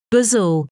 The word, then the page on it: bazel: British English